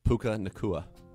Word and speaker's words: Puka Nacua: I got some quick hitters for you is Puku Puka Naku Naka. How do you say it? Puka Nacua.